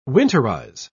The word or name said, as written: winterize